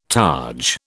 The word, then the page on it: targe: British English